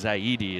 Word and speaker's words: Zaidi: Farhan, I think it's Zaidi is how you pronounce his last name.